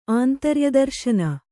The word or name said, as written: ಆಂತರ್ಯದರ್ಶನ